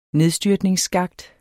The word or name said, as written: nedstyrtningsskakt